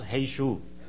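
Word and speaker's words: Jesús: You know, they pronounce Hey Shu.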